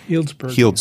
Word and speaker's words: Healdsburg: Healdsburg.